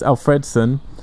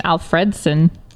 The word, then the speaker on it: Alfredson: I just like how you pronounce his name, Alfredson. – Alfredo. – But that's what it's called, Alfredson. – It's Alfredson. – Yeah. – You're putting the emphasis on the wrong syllable.